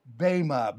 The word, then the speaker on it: Bema: Here he's talking about, as we left off last time, the rapture of the church and the Bema, B-E-M-A, long E, pronounced as an A almost.